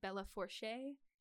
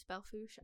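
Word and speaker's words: Belle Fourche: Over time, with natural erosion processes like the wind, and then combined with the nearby Belfouche River. Belfouche is French. It looks like Bella Fourche. It's Belfouche, I promise.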